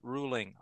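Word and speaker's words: Ruhling: My email is my last name, R-U-H, the H is silent, ruling, R-U-H-L-I-N-G 7 at Yahoo.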